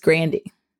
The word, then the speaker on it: Grande: We've been saying Ariana Grande incorrectly. It's Grandy.